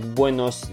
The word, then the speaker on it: buenos: «Ола», тоже, когда говорите «buenos días», заметьте, что «эска» пишется «buenos días», но никто не произносит Buenos Dias. Эска не произносится как S, Buenos Dias, а произносится Buenos, она немножко шипящая такая.